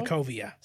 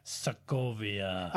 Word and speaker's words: Sokovia: Sokovo? – Sokovia. – Sokovia.